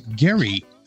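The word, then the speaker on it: Gerry: Massachusetts Governor Elbridge, I think he was actually pronounced Gary, Elbridge Gary, has been linked to this creation of oddly shaped maps to achieve overly partisan goals.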